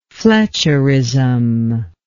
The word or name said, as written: fletcherism